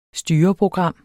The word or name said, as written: styreprogram